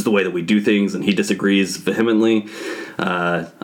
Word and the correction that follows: vehemently: Yeah, vehemently. They disagree. It's early. – That's a subtitle right there. – Yeah, you put the. It sounds like you put the emphasis on the wrong syllable there, but continue.